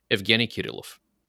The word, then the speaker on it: Evgeny Kirillov: So the way I would genuinely pronounce that, if I were like speaking Bulgarian with another Bulgarian, I would say Evgeny Kirillov.